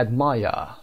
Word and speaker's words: admire: در بریتیش میگیم admire. آر تلفظ نمیشه.